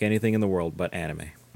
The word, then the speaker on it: anime: Amine. – It's pronounced anime, Dad.